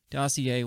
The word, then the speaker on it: dossier: However, the dossier, how do you pronounce that? – Dossier. – Why is there an R in it? – It's freaking French people. – It's French.